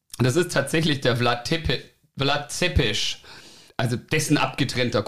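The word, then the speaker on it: Țepeș: den Beinamen hatte Vlad Tepes, was so viel heißt wie Vlad der Pfähler. – Ja, spricht man aber auf Rumänisch Zepes aus. Vlad ähm, Zepes. Vlad Zepes von mir aus.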